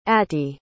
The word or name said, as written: attie